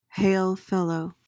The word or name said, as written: hail-fellow